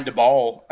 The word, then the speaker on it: Daboll: Brian Daboll, I think that's how you say his name, Dable.